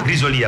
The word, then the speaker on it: Grisolia: A Civita, Grisolia, Santa Severina, Grisolia, pardon, brava, mi ha messo l'accento, e Bova.